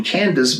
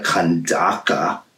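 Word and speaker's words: Kandake: And there was an Ethiopian, a eunuch, a court official of Candace, or better pronounced or known not as a proper name, Candace, but as a dynastic name, the Kandaka, queen of the Ethiopians, who was in charge of all her treasure.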